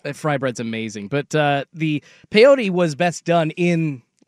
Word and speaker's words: peyote: I've done peyote one time at a powwow that It's pronounced peyote.